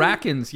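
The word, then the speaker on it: Rachins: Rackins, yeah. – Is it Ratchins? – It's spelled Ratchins, but I think it's Alan Rackins.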